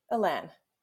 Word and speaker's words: Elan: Alan.